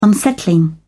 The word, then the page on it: unsettling: British English